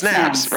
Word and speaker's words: Snabes: Well, it rhymes with crabs. Snaps. It's Snabs. Rhymes with crabs, yeah.